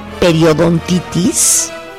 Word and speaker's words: periodontitis: tienen aproximadamente 3 veces más riesgo de desarrollar periodontitis, que es una infección en las encías, lo dije bien, Ale.